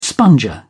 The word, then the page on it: sponger: British English